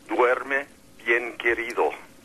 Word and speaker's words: Duerme bien querido: What that cross had written on it, the correct pronunciation is Duerme bien querido, which means sleep well, my love.